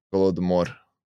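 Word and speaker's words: Golodomor (Голодомор): Um, Haladimir is an event. Uh, hold on, it says it in Russian. I pronounced it wrong because it's written in English, but it says it in Russian. Golodomor.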